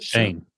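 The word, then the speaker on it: Schoen: Is it Shone? Wait, what is it? – Shane. – How's it not Shane? Like Shane Bacon. Just Shane.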